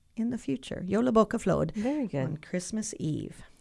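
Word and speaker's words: Jólabókaflóð: but anyway, I just love Jolabokaflod. I was going to ask you to say it again. – Jolabokaflod.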